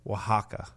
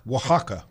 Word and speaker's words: Oaxaca: Oaxaca. Thank you. Oaxaca. You could have given me 100 guesses. I would not have come up with Oaxaca because it's spelled O-A-X-A-C-A. That is a lot of vowels. O-A-X-A-C-A.